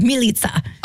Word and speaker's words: Milica: But when they named my mom, they named her Melissa, but it's spelled M-I-L-I-C-A. – Oh. – Yeah, and the way that you would say it was like Militsa.